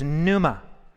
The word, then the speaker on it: pneuma: In the New Testament, we see the word ruach in Hebrew, translated spirit as meaning in the New Testament in Greek, is pneuma. So um, pneuma, this P N E, you know, right? That the, the silent P, right?